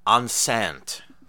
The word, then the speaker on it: enceinte: And to follow up, one word I've never said out loud with another, enceinte, I believe is how it's pronounced. E-N-C-E-I-N-T-E, pregnant.